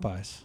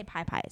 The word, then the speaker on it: Popeyes: Popeyes. – He used to say pie pies. – Pie pies. Pie pies. – It's not pie pies.